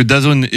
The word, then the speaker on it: DAZN: Mais voilà, les droits ont été attribués donc à DAZN, c'est comme ça qu'on le dit, ça s'écrit D-A-Z-N, mais c'est DAZN.